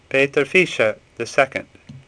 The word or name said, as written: Vischer